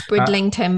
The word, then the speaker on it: Bridlington: I believe it's pronounced Bridlington. Some people might say it's Bridlington. I'm reading it as Bridlington. – It's Bridlington, mate.